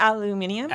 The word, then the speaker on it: aluminium: I was a little bit annoyed that every commentary about him leaving had to mention the way he pronounced aluminum. – I mean, he just pronounces it the way they do in the UK.